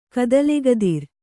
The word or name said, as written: ಕದಲೆಗದಿರ್